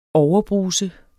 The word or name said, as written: overbruse